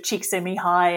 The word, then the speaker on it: Csikszentmihalyi: The work of Csikszentmihalyi and the concept of flow, I came across that in early 2000. – You know how to say his name.